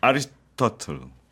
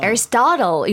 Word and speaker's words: Aristotle: 아리스토텔. – 토틀? You mean 거북이 말씀하시는 거 아니죠? – 네. – 자, Aristotle 이렇게 발음을 합니다.